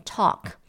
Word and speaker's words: talk: 那个 take 呢，它是 T A K E，但是我们讲话的这个 talk，它是 T A L K，A L 它其实是发 O 的音，好，所以它才会念 talk。